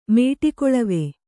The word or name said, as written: ಮೇಟಿಕೊಳವೆ